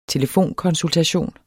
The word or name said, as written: telefonkonsultation